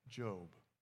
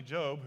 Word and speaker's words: Job: Especially Job, who really had a lot of misery. So you think, hey, my life's not as bad as what his was. So Job, and you don't pronounce it Job.